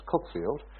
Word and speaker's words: Cuckfield: And I'm looking at them, and he said, I've just opened up my own gallery in Cookfield, which is called Cuckfield, but it's pronounced Cookfield.